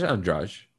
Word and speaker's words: Andrade: She's Andrade. – And Jessica Andrade. That's how they pronounce it.